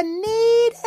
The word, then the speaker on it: Knead: Come on in to Need Another, but need is spelled K-N-E-A-D like kneading dough.